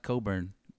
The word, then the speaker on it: Cockburn: his name sure does look like Cockburn. I don't know how his name is pronounced. Coburn. That's what I kept saying. It took me out of the broadcast because they're like, "Ain't Coburn." I was like, "That don't look like no Coburn to me."